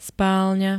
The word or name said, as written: spálňa